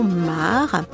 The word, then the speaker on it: M3A: Dans l'Allier, un bal à Hérisson à 20h et à Plumeur, dans le Morbihan un spectacle avec le trio MAR qui s'écrit M3A Samedi 9 décembre, dans le Loir-et-Cher à Saint-Julien-de-Chédon, Balfolk avec Grand Loup et Atrebois.